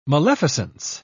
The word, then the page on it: maleficence: British English